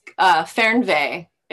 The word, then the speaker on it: Fernweh: It's Fernweh. It's spelled Fernweh.